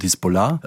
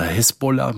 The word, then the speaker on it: Hisbollah: Ja, es hängt immer davon ab, ob du es arabisch aussprichst. Wenn, wenn man es richtig arabisch ausspricht, dann wird es am Ende auf der letzten Silbe betont Hisbollah äh, oder Hezbollah mit E dann auch.